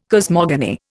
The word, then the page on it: cosmogony: Indian